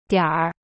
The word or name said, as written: dian3r5